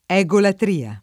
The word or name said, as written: egolatria